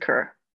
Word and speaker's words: Kerr: We pronounce it Kerr.